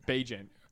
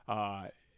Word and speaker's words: Bagent: If you're Justin Fields and they've just promoted Badgley, is that how you pronounce it? Bagent. – Bagent. Yeah, secret Bagent man, that's right.